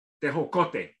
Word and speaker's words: tejocote: And they've mentioned several times in it Mexican hawthorn, tejocote. Is, am I saying that correctly? – Uh, I got Mexican, yeah, uh, uh, tejocote, yeah.